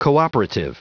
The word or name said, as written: cooperative